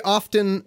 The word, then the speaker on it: often: Often, K. Well, do you pronounce the T in often? I shouldn't.